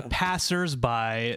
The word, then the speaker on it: passersby: The passersby throws me off, and I've even written down in the show notes that just it's a silent S.